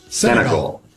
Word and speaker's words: Senegal: Senegal.